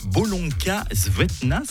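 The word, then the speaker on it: Bolonka Zwetna: Vous avez adopté un Bolonka Svetna, c'est ça? Est-ce que je le prononce bien déjà? – Oui. Exactement, tout à fait.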